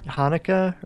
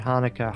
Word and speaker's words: Hanukkah: Hanukkah or Chanukah, as some people pronounce it. Oh, do they? I never heard it called that before. – Well, I always thought it was spelled with an H, but apparently it's supposed to start with a CH, but you pronounce it Hanukkah, Hanukkah, something like that.